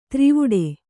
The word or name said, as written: ತ್ರಿವುಡೆ